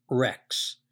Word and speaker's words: rex: Now, if you take a look, if we take that stem, reg, R-E-G, and we add to it an S and pronounce it, we get regs, regs. So we have a guttural, a G, followed by an S, which in linguistic formula ends up being an X, rex.